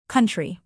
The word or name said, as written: country